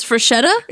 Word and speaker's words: Freschetta: But the reason we wanted to do this and not gloss over it is because I had been pronouncing that freschetta. Like Giada di Laurentiis. I had been saying freschetta. – So it's freschetta? – But it's pronounced freschetta.